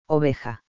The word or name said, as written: oveja